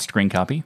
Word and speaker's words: scrcpy: I mean, it is pronounced Screen Copy, for those who are interested.